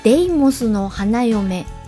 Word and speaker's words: 悪魔の花嫁: 伊藤和恵さんは私が好きな女優さんの一人で、漫画悪魔の花嫁と書いてデイモスの花嫁と読む、イメージアルバムのレコードを擦り切れるまで聞いたものです。